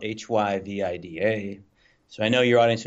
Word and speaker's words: HyVIDA: So HyVIDA. So it rolls off. It's three syllables.